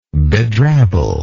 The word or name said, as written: bedrabble